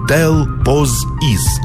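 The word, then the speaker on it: Тельпосиз: Или взять, к примеру, горную вершину на Северном Урале под названием Тель-По-Сис или Тел-Поз-Из. Вариантов произношения, кстати, очень много.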